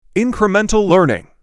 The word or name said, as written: Incremental learning